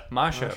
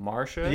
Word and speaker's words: Masha: and the great Sandra Bernhard as Masha, right? That's how they say it. I could never really hear it. At first, I thought they were saying Marsha.